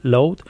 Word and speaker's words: Loud: Lo puedes hacer a través de Spotify, ahí tienes el canal de Load, se escribe L-O-U-D, Load, y bueno, pues ahí vas a encontrar temas muy interesantes de esta banda española.